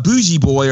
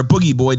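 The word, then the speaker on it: Booji Boy: Bougie boy or boogie boy, depending on how you pronounce it, is the character created by Mark Mothersbaugh of Devo.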